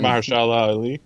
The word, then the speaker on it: Mahershala Ali: You can say his name way better than I can. – Mahershala Ali. – There we go. You got that.